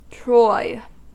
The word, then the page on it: troy: American English